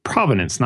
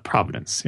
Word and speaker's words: provenance: Provenance, not providence. Yes, it's a pronunciation difficulty there.